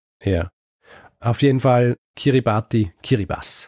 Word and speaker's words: Kiribati: und unter anderem einen kleinen Inselstaat, den ich Kiribati nenne. Ja? Tatsächlich spricht man das TI in dieser Sprache wie ein Doppel-S aus. Also eigentlich heißt es Kiribati.